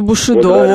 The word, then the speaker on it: Бушидо: Давайте, Бушидо. Вот теперь мы ударение узнали. Вспомнили, точнее.